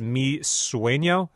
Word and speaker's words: Mi Sueño: Ziggy Dreams in Color is the album, and this song is Mi Sueño. Am I saying that right? – Correct.